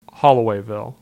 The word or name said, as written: Hollowayville